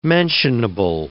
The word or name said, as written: mentionable